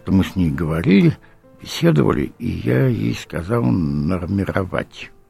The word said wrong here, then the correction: нормировать: Ну как, нормировать? Она точно знала классическое московское произношение, классическое московское ударение.